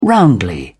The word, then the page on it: roundly: British English